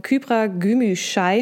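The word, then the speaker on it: Kübra Gümüşay: Ich bin sehr gespannt auf Kypra Gümüşay. Habe ich das richtig ausgesprochen? – Hast du glaub, genau richtig mh. ausgesprochen.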